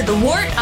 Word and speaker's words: Wort: It is the Wort, not Wort, not Fort, not Short. The Wort.